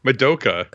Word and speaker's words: Madoka: Magical Makota. – Makota, what is that even? – Madoka. – Yeah, I said it wrong.